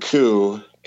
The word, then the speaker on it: coup: We're not giving enough attention here to the fact that Jason just read the word coup and pronounced it correctly.